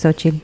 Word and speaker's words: Xochitl: It doesn't look like Xochitl. – No, es Xochitl. – And I'm pretty sure it has like a Xochitl.